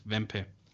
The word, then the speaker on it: Wempe: No, actually, Wempe is a German brand, German retailer, and that's how you pronounce it, Wempe.